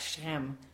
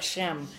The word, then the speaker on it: Shechem: Say it like that, Shechem. And it'll blur it all together, Shechem. Shechem, all right? Kind of get a ch in the middle of that.